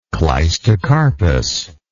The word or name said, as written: clistocarpous